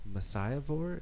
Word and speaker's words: Messiah Vore: But you guys are all from the band Messiah Vore. Am I saying that right? – You are. – Mm-hmm. It's like Messiah Whore, but with a V.